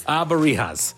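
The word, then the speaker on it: Alebrijes: Now, Day of the Dead doesn't really involve. – Abarijas, which are spirit animals that guide you to the afterlife.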